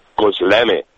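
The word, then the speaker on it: Gözleme: I've just done a demonstration and I was given the right pronunciation. Gözlemi. – Gözlemi.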